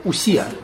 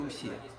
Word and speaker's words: усия: То, что мы называем существительным, это аристотелевская усия. – И она является обычно субъектом предикации. Да? Человек, там, Сократ э, есть Сократ, тоже усе, условно говоря. Произносится «у», это по-гречески читается «у».